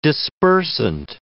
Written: dispersant

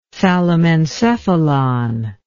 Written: thalamencephalon

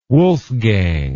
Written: wolfgang

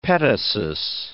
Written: petasos

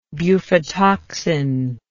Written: bufotoxin

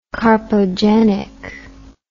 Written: carpogenic

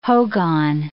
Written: hogan

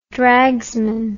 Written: dragsman

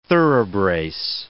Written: thoroughbrace

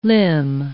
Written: limn

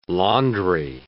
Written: laundry

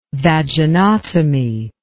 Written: vaginotomy

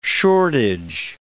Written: shortage

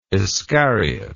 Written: Iscariot

